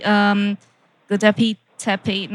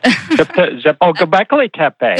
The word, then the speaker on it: Göbekli Tepe: And obviously, Göbekli Tepe. No, I totally pronounced that wrong. But Göbekli Tepe. Göbekli Tepe in Turkey.